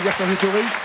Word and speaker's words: Vercingétorix: Avec Vercingétorix. Statue équestre réalisée par Bartholdi et la statue de Dezay. Et on ne prononce pas le X à la fin.